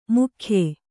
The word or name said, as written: ಮೂಗೆಯ್